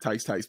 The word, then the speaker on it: Tice: I know how to say his name now because of Stacy, because I think Tice, baby. Like, I appreciate it.